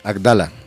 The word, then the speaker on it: Addala: Adala, doble D.